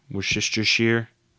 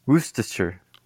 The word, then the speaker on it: Worcestershire: How do you say it? – Worcestershire. – Whoa, what is that? You probably say it closer to me. You probably say it closer to the correct version because you live closer to England.